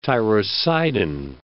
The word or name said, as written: tyrocidin